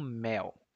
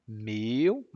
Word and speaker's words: mel: Atenção à pronúncia, hein? Mel. Não meu, mas mel. Meu é outra coisa.